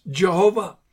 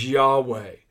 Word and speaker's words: Yahweh: Some have said that it should be pronounced Jehovah, but that's impossible because there's not a J in Hebrew. Some say it should be Yahowah or Yahovah, but I tend to believe the correct pronunciation is Yahweh, the personal name of God.